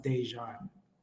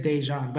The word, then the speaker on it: Déjan: So my middle name is pronounced Déjan. – Oh, my apologies. So Déjan. – No, it's okay. Arys, Déjan.